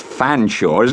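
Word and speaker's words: Featherstonehaugh: "It's hard to believe it's pronounced Fanshawe, isn't it?" – he remarked.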